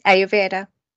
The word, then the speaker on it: Ayurveda: But I'm going to throw a bit of a curveball to start with, because can you explain, and I'm please correct me if I've mispronounced this, did you say it's Ayurveda yoga? – Mm-hmm. Ayurveda.